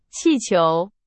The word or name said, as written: 气球